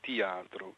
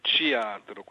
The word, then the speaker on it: teatro: A Rio de Janeiro, la mia pronuncia non è proprio esattamente carioca, io non sono di Rio, è teatro, quella T diventa liquida, non so come spiegarlo, diventa praticamente un TCH.